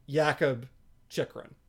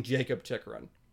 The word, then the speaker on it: Jakub Chychrun: My understanding has always been that it's pronounced Jakub Chychrun instead of being Jacob Chychrun.